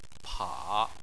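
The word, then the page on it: pa3: tone 3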